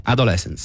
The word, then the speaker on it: Adolescence: però lui è già autore di Adolescence. Si pronuncia così? – Adolescence, sì. – Adolescence. – Sì. – Io ho detto Adolescence. – Ma l'hai detto bene.